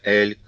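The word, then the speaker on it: aile: По-французски согласное «л» произносится «эль», так же, как слово «эль» — крыло.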